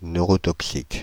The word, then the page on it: neurotoxique: /nø.ʁɔ.tɔk.sik/